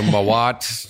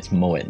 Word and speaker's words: Mouat: My team just tell me it's Mouat now rather than Mouat, but who knows? – You're good with it.